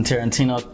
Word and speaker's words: Tarantino: Yeah, Thoughts Tarantino. – With Quentin Tarantino, yeah. – Talk about, that one was hard. That one was hard. How did that record come about? – I met Quentin Tarantino through a performance.